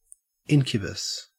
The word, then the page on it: incubus: Australian English